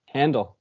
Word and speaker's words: Handel: Handel.